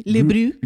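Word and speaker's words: Bruit: y estaremos escuchando el disco de Brut, no sé cómo se pronuncia, Bru, Le Bru.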